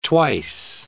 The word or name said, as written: twice